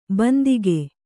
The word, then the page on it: ಬಂದಿಗೆ: bandige